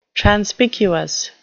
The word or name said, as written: transpicuous